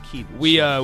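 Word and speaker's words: Kedon: We were debating the pronunciation of, yes, Keydon Slovis. It is Keydon. – We looked up a video of him saying the name, and he says Keydon.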